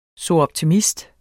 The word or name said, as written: soroptimist